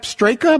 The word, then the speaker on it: Straka: Sep Straka, am I pronouncing Straka. – That? Straka. Sep Straka finished tied for second in the Open Championship.